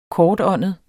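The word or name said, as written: kortåndet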